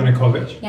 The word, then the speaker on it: Yanukovych: Yeah, Yanukovych.